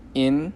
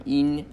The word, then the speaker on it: yin: If you want to say this and really impress either me or your Chinese friends, yin yang, yin, but like a long e sound, yin yang.